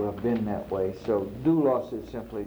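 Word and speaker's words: doulos (δοῦλος): It's doulos. Doulos. And that little squiggly at the beginning is a delta, and it's pronounced like a D. So it's sort of like D-O-U-L-O-S.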